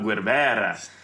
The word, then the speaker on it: Guevara: Did I say that right? Is that the Latin way to say it? – Si, senor. Si. – Oh, I got it down. Rolled my tongue, Guevara.